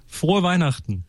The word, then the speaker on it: Frohe Weihnachten: Frohe Weihnachten. – Frohe Weihnachten.